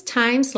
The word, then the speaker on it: times: It's always times like these, times like these. Atenção a esses E's que não são pronunciados, que estão pintadinhos de cinza, tá bem? Times, do M você já vai pro S.